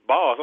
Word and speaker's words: Bas: I called him Boss, and I thought it was Bass, so I said Bass, and he was like, no, it's Boss.